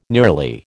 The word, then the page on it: nearly: American English